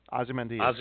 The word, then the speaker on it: Ozymandias: Ozymandias.